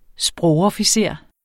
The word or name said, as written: sprogofficer